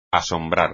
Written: asombrar

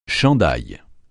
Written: chandail

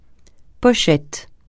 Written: pochette

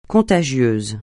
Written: contagieuse

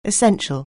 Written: essential

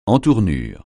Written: entournure

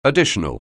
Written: additional

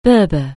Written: Berber

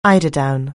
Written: eiderdown

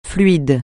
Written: fluide

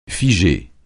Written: figer

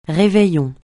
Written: réveillon

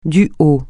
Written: du haut

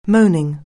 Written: moaning

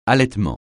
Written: allaitement

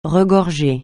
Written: regorger

